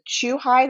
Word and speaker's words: chuhai: It's a chuhai, that's how you pronounce it? – Yeah.